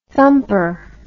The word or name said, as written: thumper